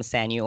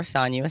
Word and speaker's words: Sanyu: I am Sanyu or Sanyu Estelle. Either pronunciation is fine because my parents have different accents, and so they always said my name differently.